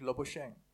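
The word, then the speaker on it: Labuschagne: Although both failed to get to their centuries and both were out on 94 runs and both uh, wickets were taken by Labushagni. It's, it's a bit pr- difficult to pronounce. How do you pronounce it, sir? Labushagni? – Uh, I had heard uh, Australian uh, commentator pronouncing it Labushang.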